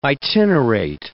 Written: itinerate

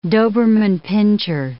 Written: Doberman pinscher

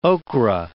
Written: okra

